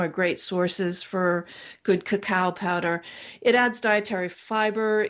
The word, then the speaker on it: cacao: And if I could be in love with a food, it would be cacao. Now that's, people say, is that the same as cocoa? But it's the raw unadulterated bean that comes from South America or Mexico. It's C-A-C-A-O, pronounced cacao.